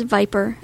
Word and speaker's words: VIPRE: And if you do a search for it, it's V-I-P-R-E. Eloquence pronounces it Viper. But it is Viper, just spelled a little differently, V-I-P-R-E.